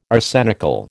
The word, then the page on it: arsenical: American English